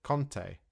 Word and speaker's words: Conte: For Conte, the manager, there is an O sound, so we say Conte.